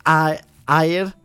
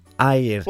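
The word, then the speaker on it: aur: Seren Ayr Arwythnos. – Excellent. Bendigedig. – Bendigedig. I can't say gold in Welsh. It's A-U-R Ayr.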